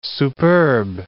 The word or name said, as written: superb